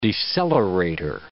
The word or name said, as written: decelerator